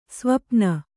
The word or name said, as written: ಸ್ವಪನ